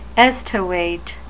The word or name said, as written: estivate